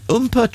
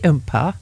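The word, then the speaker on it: oompah: The Umpah Trio present Oktoberfest. – Is it Umpah? – Umpah. – Is that how you say it? – Yeah, Umpah. Have you never heard of an Umpah band? – Umpah, Umpah, stick it up your jumper.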